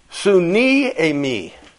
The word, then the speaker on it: suniemi: It comes from the Greek word, suniemi. Suniemi. You know, make it up if you're going to write it down. It's spelled S-U-N-I-E-M-I.